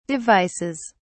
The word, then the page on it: devices: British English